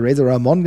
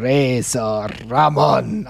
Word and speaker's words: Razor Ramon: dass er nicht wie ich es vielleicht sagen würde, Razor Ramon genannt wurde, sondern Razor Ramon. Also diese Aussprache, diese, ähm, ähm diese Aussprache in diesem Puerto ricanio style könnte man fast sagen,